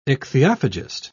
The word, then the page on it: ichthyophagist: British English